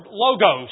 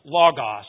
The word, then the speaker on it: logos: The word logikos, which is used here and translated as reasonable in the King James translation, comes from the Greek word logos, or sometimes pronounced logos, L-O-G-O-S.